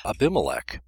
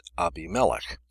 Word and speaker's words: Abimelech: The name of the king in the story in the first part is usually pronounced Abimelech by most English speakers, but the Hebrew renders it Abimelech. I tend to use the latter for some odd reason.